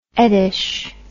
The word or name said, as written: eddish